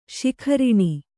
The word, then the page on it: ಶಿಖರಿಣಿ: śikhariṇi